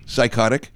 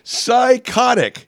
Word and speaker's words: psychotic: Name another word with a silent P. – Psychotic. – What? – Psychotic.